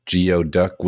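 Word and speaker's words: geoduck: There's frog in garlic hot pot, geo duck with yellow chives. – Wow. – But it's pronounced gooey.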